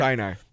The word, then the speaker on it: Sinai: Sinai.